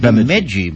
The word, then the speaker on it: Bemidji: Lowell Thomas pronounces it Bemidji. – Bemidji. Sounds Bemidji. Like an eye test.